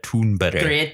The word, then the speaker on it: Thunberg: I heard someone pronounce her name and it's actually Greta Thunberg. – Greta it's Thunberg.